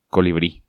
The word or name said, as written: Colibri